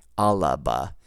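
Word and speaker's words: Alba: Well, there's no, it's not spelled A-L-A-B-A, Alaba. It's A-L-B-A. So, but when you pronounce it, you just the way they pronounce, the Gaelic speakers do it, it'll sound more like Alaba.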